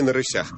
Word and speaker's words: на рысях: Гражданскую панихиду провели на рысях. – провели на рысях, конечно. Я прошу прощения за неправильное ударение.